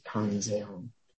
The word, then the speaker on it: Kanzeon: And in Japanese, you pronounce it Kanzeon.